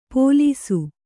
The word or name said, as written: ಪೋಲೀಸು